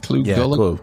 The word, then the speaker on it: Clu Gulager: Clu Gulager.